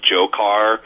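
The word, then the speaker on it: Dzhokhar: By the way, his name is pronounced Jokar.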